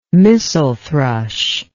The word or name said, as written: missel thrush